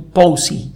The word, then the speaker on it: Pawsey: Esto ocurrió en el centro de supercómputo POSI, se escribe P-A-W-S-E-Y, está en la ciudad de Perth,